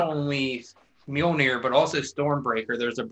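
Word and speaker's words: Mjölnir: Mjolnir, whatever, you both got it, that comes to his hand, which means he is worthy to wield the hammer Mjolnir.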